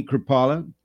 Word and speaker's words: Krupala: Today I have a very special guest, Miss Whitney Krupala, who is running for MISD School Board Place 4.